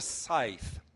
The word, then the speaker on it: scythe: This is called a scythe. It's spelled S-C-Y-T-H-E, but it's pronounced scythe.